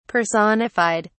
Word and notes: personified: British English

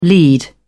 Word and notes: lead off: British English

